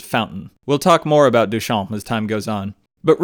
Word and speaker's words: Duchamp: The French artist whose name is spelled Marcel Duchamp, but pronounced Marcel Duchamp,